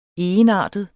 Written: egenartet

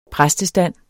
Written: præstestand

